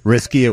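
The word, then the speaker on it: Riske: I should have called the girl, Allison Risk, and said, from now on, could you please pronounce it Risky? It would be better for Dave and for the world. – I'm assuming it's R-I-S-K-E.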